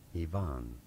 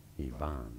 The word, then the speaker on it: Ivan: Well, in English it is generally said as Ivan, with an emphasis on that second syllable, Ivan.